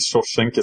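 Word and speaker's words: Shoshenq: Shawshank, actually, he has many pronunciations of his name. I think Shawshank is the correct one.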